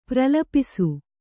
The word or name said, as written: ಪ್ರಲಪಿಸು